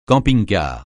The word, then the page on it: camping-car: francais